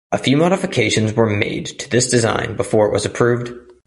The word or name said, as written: modifications